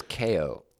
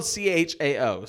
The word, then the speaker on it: Chao: I thought it was KO. – It's spelled CHAO, so And there's the Chaos Emeralds. So yeah. See, that's what I thought for the longest time, but at some point I heard them being referred to as Chao.